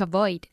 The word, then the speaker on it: avoid: To avoid. – Good, to avoid. Con una V muy bien pronunciada. Avoid.